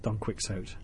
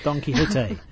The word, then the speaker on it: Don Quixote: Not Don Quixote, exactly. It's Don Quixote, as in Man of La Mancha, as in to dream the impossible dream.